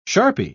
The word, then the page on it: sharpie: British English